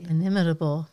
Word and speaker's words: inimitable: What's inimitable? Is that it? – Inimitable. – Inimitable.